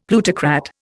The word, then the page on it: plutocrat: Indian